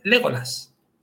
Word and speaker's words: Legolas: El acento va en la tercera sílaba. Las palabras son esdrújulas. ¿Vale? Legolas, Faramir, Boromir. Esa va a ser la regla.